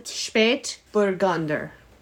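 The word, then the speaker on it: Spätburgunder: But the type of wine, Spät Burgunder. – Burgunda, yeah. – Spät rhymes with late because it's referring to the lateness of the ripening of this grape. Burgunder, like Burgundy, because this is Pinot Noir.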